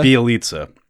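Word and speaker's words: Bjelica: Bielitsa. – Bielitsa. When you actually write it, it's like, whoa, I can't believe that it's pronounced that way. Bielitsa.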